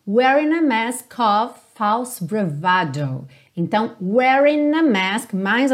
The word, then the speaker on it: bravado: Então, como ela vem do italiano, a pronúncia fica ali no meio do caminho, bravado, bravado.